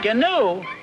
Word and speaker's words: gnu: Gnu? It's G-N-U, gnu. The G is silent.